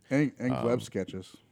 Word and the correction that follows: Glebe: It's Glebe. I found out we were pronouncing that wrong the other day.